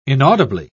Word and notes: inaudibly: British English

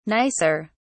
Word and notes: nicer: British English

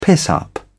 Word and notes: piss-up: British English

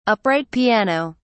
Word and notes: upright piano: British English

